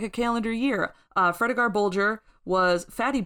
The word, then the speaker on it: Fredegar: And then we see a familiar, unfamiliar face in Fredegar.